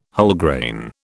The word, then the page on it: Wholegrain: British English